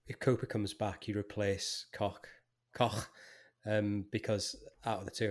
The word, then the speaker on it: Koch: But what I thought Cock can should point out that it's pronounced Koch, and you use the H as in from the word home. So we do understand that. that you pronounce it like that. – So Koch.